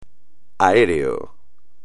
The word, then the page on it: aéreo: espagnol